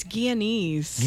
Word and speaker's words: Guyanese: It's Guyanese. – Guyanese.